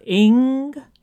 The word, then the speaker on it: Inge: He said this: I've been listening to the Christmas special and thought that the quotation from Dean Inger is that I use in the book. I mean, I don't know. Is he just trying to plug the book again? – Dean Inge? Or Ing? – Ing.